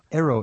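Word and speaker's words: Ærø: Aero is spelled A-E-R-O.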